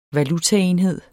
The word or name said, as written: valutaenhed